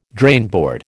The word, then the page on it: drainboard: American English